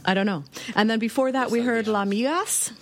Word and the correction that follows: Las Migas: Las Migas. – Oh, it's Las Migas.